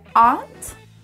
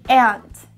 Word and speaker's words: aunt: Tia, você até pode usar duas pronúncias diferentes, que é aunt ou aunt. Só que quando você usa aunt, você também diz formiga.